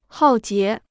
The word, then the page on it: 浩劫: hào jié